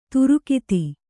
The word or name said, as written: ದೀರ್ಘತೆ